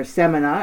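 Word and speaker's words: Samaná: The next one kind of near and dear to our hearts, there's Cayo Levantado in Samana, or just off Samana, or Samana, as we learned, is the Yeah. – correct way to say it.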